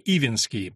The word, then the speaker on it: Ивинский: Предок был некий дворянин по фамилии Ивинский, почему-то его произносили с ударением на первый слог, который вдруг воспринял малаканские идеи, отказался от дворянства, распустил крепостных и уехал в числе прочих единомышленников в Персию.